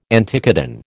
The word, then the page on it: anticodon: American English